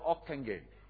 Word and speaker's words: Ockenga: It was founded by a man called Harold Okenga, O-C-K-E-N-G-A. Some pronounce his name as Harold Okenga.